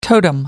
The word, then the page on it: totem: American English